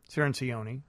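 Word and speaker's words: Cirincione: Cinceroni, I should say. I like to pronounce his name properly. If he took the time to call us, we might as well be able to get his name right.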